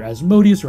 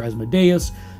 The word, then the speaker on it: Asmodeus: Uh, the biggest lawful evil deity in Pathfinder is, depending on your pronunciation, either Asmodeus or Asmodeus,